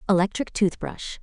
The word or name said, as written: electric toothbrush